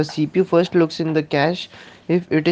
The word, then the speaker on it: cache: Cache memory, pronounced as cache, the cache, a special high-speed storage mechanism.